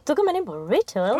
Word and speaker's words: Rituals: Nej, men du, vänta, vi måste stanna vid hur du uttalar Rituals. – Är det inte så? Rituals. British. – Tydligt, men också rituals. Ingen P.